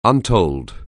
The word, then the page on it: untold: anglais